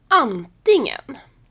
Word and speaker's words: antingen: Nästa ord är antingen antingen uttalas inte med ett hårt g utan med ett ng ljud.